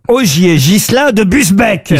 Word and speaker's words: Busbecq: Ogier Gilin de Busbecq, pardon, j'ai écorché son nom. – Il me semble bien aussi. – Est-ce que vous pouvez appeler Busbecq? – B-U-S, B-E-C-Q. Olivier, pardon, Augier Ghislain de Busbecq.